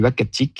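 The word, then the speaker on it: Uhackatic: Et donc, on s'est mis d'accord sur Wakatik, qui désigne un endroit propice à la chasse du caribou.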